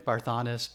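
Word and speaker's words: Barthanes: I forget how we pronounce his name. – Sounds right. Barthanis.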